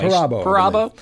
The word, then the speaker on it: Perabo: Piper Perabo, I believe I'm pronouncing her name right, is Perabo? Anyway, Perabo.